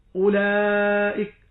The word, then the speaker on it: أولئك: وهكذا في جميع النظائر، عندما تجد أولئك هناك واو فيما بين الألف المهموزة واللام، هذه الواو وضع عليها سكون مستدير ليدلك على أن هذه الواو لا أثر لها في النطق لا في حالة الوصل ولا في حالة الوقف.